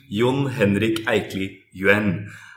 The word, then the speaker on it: Jon Henrik Eikli Yuen: Well, the correct pronunciation of my name is unfortunately influenced by two weird cultures, the Norwegian and the Chinese one, and hence the pronunciation Jon Henrik Eikli Yuen. So a lot of vowels, a lot of consonants, and a lot of syllables.